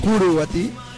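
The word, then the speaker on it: Curuguaty: Se dicta sentencia sobre la masacre de Caraguatí, así se pronuncia, Curuguatí. Tiene problemas con el guaraní.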